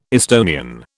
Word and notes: Estonian: British English

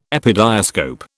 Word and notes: epidiascope: British English

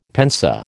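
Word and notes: pensée: American English

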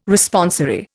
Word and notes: Responsory: Indian

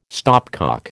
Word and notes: stopcock: American English

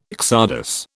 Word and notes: csardas: British English

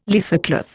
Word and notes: leathercloth: Indian

